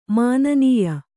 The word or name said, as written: ಮಾನನೀಯ